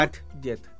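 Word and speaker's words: Atget: se escribe Eugene, Eugene, Eugene, ¿ya? – Ajet, Ajet.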